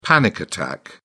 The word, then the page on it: panic attack: British English